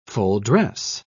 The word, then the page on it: full-dress: British English